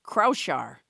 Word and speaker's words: Kraushaar: I don't even know how to say that. Crouchar. – You just look to Captain Crinkle for the pronunciation of that place. – K R A U S H A A R. – It's the Crouchar.